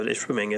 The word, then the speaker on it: Ishpeming: Hey, you said it right. Look at, good job. Last week he knew what odd numbers were. And this week, he finally learned how to say Ishpeming correctly.